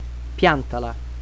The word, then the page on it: piantala: Italian